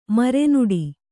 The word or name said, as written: ಮರುನುಡಿ